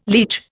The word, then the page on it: leach: Indian